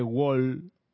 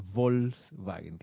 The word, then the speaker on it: Volkswagen: Ah, Arraxa, claro, Volkswagen eh, se dice con V, no se dice Wol, Volkswagen, ¿no? Sino Volkswagen, claro, muy bien.